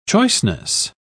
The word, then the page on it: choiceness: British English